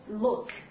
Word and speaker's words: look: 注意它的发音啊，中国同学通常容易把它发成非常轻松的 lu 啊 look。但是呢，我们发现，注意这个发音，它是一个典型的，在英语当中是一个啊 back vowels 后元音。那么发音的时候，靠后，整个的口型啊，舌位靠后，我们发一下，oh look，对不对？look。